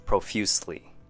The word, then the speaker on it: profusely: First word, profusely. 3 syllables. Pro has the short U sound. Few sounds like the word F-E-W, few, and sli, profusely.